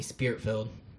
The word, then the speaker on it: Spirit-Filled: They call me Spirit-Filled. – Spirit-filled. Okay, right, spell it for me. I'm not hearing it. – S-P-I-R-I-T.